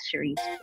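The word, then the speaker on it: Cerese: So if you want to listen to my music on social, on Apple Music, Spotify, it's CERESEEJ, and my name is pronounced Cherise.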